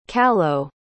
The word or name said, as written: callao